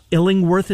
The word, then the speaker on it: Illingworth: Illingworth is how you say his last name.